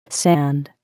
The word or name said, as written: sand